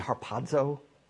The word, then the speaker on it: harpazo: In the Greek language, when you see a Z, commensurate with a Z, Zeta, it has like a DZ sound. So if you were pronouncing it, it would be harpazo.